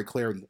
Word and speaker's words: Clardy: At Troy Clarity on Twitter, at Troy Clarity, last name is spelled C-L-A-R-D-Y.